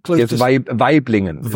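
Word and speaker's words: Waiblingen: You were born in, Weiblingen, I think is the pronunciation in West Germany. – Weiblingen.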